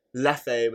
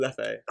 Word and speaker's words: Leffe: And did get some feedback from someone saying, I can't believe that you said Leffe was Leff. And he said it tasted like... – Is it pronounced Leffe? – Yeah, apparently it's Leffe.